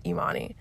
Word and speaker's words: Yemane: It's spelled like Yemane, so Y-E-M-A-N-E, but it's pronounced Imani.